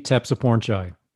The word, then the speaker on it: Tepsupornchai: Yeah, it's Ekkie Tepsupornchai. So it's it actually rhymes with tech support guy.